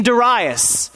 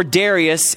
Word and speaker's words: Darius: So these governors and satraps thronged before the king and said thus to him, King Darius, or Darius, if you want to pronounce it incorrectly, live forever.